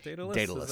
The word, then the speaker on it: Daedalus: Is it Daedalus? – Daedalus. Is that right? – Yeah.